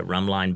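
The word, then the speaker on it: Rhumb Line: And the second book in the series is called Rum Line Bend. – Mm-hmm. – Rum Line spelled like a line on a map, R-H-U-M-B. – Mm-hmm. – So Rum Line Bend.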